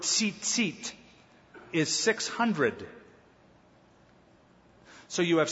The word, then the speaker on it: tzitzit: The name of this tassel in Hebrew is the word tzitzit.